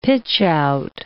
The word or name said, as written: pitchout